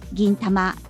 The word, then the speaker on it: 銀魂: あの銀色の銀に魂と書いて銀魂と読みます。